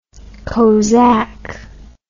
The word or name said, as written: cosaque